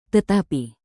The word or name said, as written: tetapi